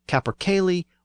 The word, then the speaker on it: capercaillie: For example, a type of chicken or cock that's native to the Scottish Highlands is either called a capercaillie or a capercailzie. The first pronunciation is the older pronunciation with the Y sound, and the second pronunciation is the newer pronunciation with the Z sound.